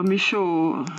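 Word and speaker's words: Michôd: Utan det finns ju även till exempel australiensare, eller skulle du hålla med mig om att David Michaud, eller hur uttalar man hans efternamn tror du? Michaud. – Ja, Michaud, ja.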